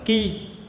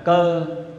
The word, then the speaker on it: cơ: Chữ này có hai âm đọc, một âm đọc là ki. Ki là chỉ cho cái sao ki. Trong nhị thập bát tú đấy, chúng ta gọi là sao cơ. Ki là cái gì? Ki là cái lia, cái long. Nên có cái sao cơ, nhưng quen đọc là cơ.